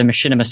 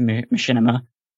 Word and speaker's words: Machinima: It's pronounced as machine cinema. That's like the long pronunciation for it. – Nice. – Okay. But yeah, they just shrunk up into Machinima.